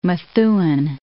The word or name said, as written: Methuen